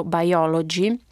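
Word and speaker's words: Buyology: Con grande chiarezza, a mio parere, questo caso, raccontato nel libro Biology, si pronuncia come biologia ma è scritto con la U del verbo comprare ed è traducibile con acquistologia,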